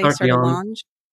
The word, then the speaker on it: Sertillanges: Sartillange. – Okay. Sartillange, friend.